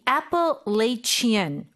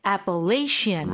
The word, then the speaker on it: Appalachian: Now, the Appalachian or Appalachian or Appalachian, there are many pronunciations of this word.